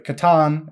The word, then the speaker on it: C'tan: But they were able to communicate with them, and they were told that they called themselves the C'tan or Satan. There's a lot of different pronunciations, or they're also known as the Star Gods. Now, the C'tan is how I will pronounce it.